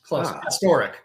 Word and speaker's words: Pastorek: Close. Pastorek. – Okay. – Emphasis on the middle syllable there.